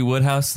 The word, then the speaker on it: Wodehouse: Woodhouse. – Where are we? – It's like the P.G. Woodhouse, the British author. So it's pronounced Woodhouse.